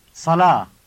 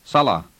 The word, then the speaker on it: صلاة: Prayer in Arabic is صلاة. – Listen to it and notice how the word ends in the long vowel sound ah.